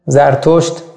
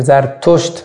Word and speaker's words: زرتشت: زرتشت یا زردشتره یا زرتشت با دال با تا هم درست تلفظش، که کتاب هم داشته اوستا، و هرچند اختلافی ولی خیلی از علما معتقدن که پیغمبر بوده.